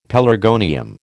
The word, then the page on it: pelargonium: American English